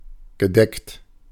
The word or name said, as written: sober